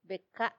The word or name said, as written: beka